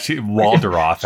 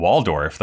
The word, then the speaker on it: Waldorf: She pronounces Waldorf in a way that it rhymes with the word off. – Yeah, Waldorf, she's like, it's a Waldorf.